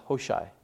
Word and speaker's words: Hushai: Hushai is pronounced Hoshai.